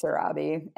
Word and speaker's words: Sarabi: And it could either be – is it Sarabi? Sarabi, right? – Yes.